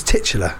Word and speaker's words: titular: I saw, it's titular, right? The titular character.